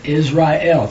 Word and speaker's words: Israel: Afterwards shall the sons of Israel, remember Israel, three syllables, return and seek Yahweh their Elohim and who? – David their king.